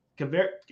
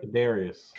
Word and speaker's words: Kadarius: I did go back and see that Caver- it's right? Am I saying it right? Kadarius.